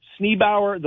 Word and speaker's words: Sneeboer: The Sneebauer, So it's spelled S-N-E-E-B-O-E-R, Sneebauer, the Royal Dutch Hoe.